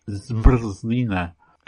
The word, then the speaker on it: zmrzlina: For example, the Czech word for ice cream is written ZMRZLINA and pronounced something like ZMRZLINA. That's five consonants in a row at the beginning of it.